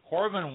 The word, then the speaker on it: Coravin: manufacturer Coravin, C-O-R-A-V-I-N, Coravin, I believe it's pronounced, has been released, allowing wine lovers to access any wine, anytime, in any quantity, without ever removing the cork.